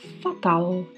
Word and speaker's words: fatal: E se você estiver horrível, péssimo, você pode dizer que está fatal, também com a pronúncia do L com a língua entre os dentes. Fatal.